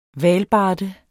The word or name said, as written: hvalbarde